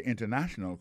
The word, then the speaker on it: international: The international community. Alors, accent tombé sous troisième syllabe.